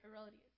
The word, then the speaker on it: Herodias: On Herod's birthday, the daughter of Herodias, Herodias, that is how you say it, Herodias.